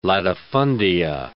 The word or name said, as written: latifundia